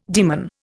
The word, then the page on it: daemon: Indian